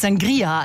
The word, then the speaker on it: Sangria: Das Getränk steht da drin, heißt Sangria, also Betonung auf dem I.